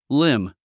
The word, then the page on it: limb: lím; リム